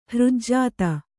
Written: ಹೃಜ್ಜಾತ

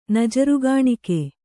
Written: ನಜರುಗಾಣಿಕೆ

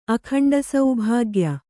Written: ಅಖಂಡಸೌಭಾಗ್ಯ

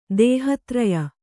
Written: ದೇಹತ್ರಯ

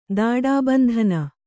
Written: ದಾಡಾಬಂಧನ

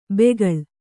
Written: ಬೆಗಳ್

